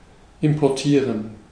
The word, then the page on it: invoeren: /ˈɪnvuːrə(n)/